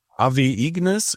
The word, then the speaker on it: Ave Ignis: Gut, und da machen wir weiter mit Niklas Lenz, Ave Ignis, oder wird es vielleicht lateinisch ausgesprochen? – Ave Ignis, ja, lateinisch, ja.